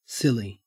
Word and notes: silly: Australian English

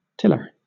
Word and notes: tiller: British English

